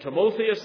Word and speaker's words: Timotheus: And when he came to Lystra, There was a certain disciple named Timotheus, that's just the Greek pronunciation there, Timothy, the son of a certain woman which was a Jewess and believed.